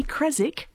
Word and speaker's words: Creswick: because it should be Creswick, not Creswick. That W should not be pronounced. – And why is that? – Because that's how Americans say Birmingham. – Yeah. – Like it's not supposed to, you know, and they go, you know, they always put that ham in. It's not all like, yeah, like there's Chiswick in England, Creswick. That's that W's there, but you don't pronounce the W.